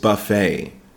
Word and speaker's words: buffet: Number nine is breakfast buffet. That's breakfast, B-R-E-A-K-F-A-S-T, and buffet, B-U-F-F-E-T. Be careful, we don't pronunciate the T on the buffet, so don't say buffet.